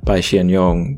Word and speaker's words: Pai Hsien-Yung: and the correct pronunciation of Pai Hsien-Yung's name should really be Pai Hsien-Yung, but this is Taiwanese lit, so we're using the strange Wade Giles.